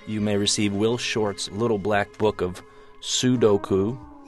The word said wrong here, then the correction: sudoku: No, it's not pronounced like that. – Oh, yeah. Sudoku.